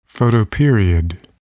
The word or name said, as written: photoperiod